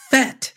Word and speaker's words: Fett: He thought it was Boba Fett with a TH sound. – Fair. Okay. Totally fair. – It's like, I've been saying this wrong for a long time. Michael's sitting there on the couch or watching, and this is Michael. It's Boba Fett, Dad. Fett.